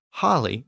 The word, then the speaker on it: holy: I should also note that the word holy was pronounced holly in Old English, and that original pronunciation has survived in the word holiday, which is literally a holy day.